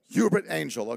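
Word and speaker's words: Uebert Angel: A man by the name of, and you have to correct me if I get his name wrong, Herbert Angel. Is that how you say it? Hubert Angel, okay.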